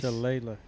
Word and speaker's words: shillelagh: Is that how you pronounce it? Shillelagh. Penis. – Shillelagh.